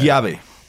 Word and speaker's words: llave: I-A-V-E. How do you say that? – Llave.